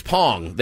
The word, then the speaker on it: Bong: It's Pong. They pronounce it Pong.